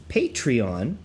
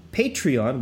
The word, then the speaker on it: Patreon: Folks, you've all weighed in, and apparently the right way to say it is Patreon. Patreon, which still sounds unnatural to me, but do us a favor and support the podcast by signing up for the RotoViz Patreon.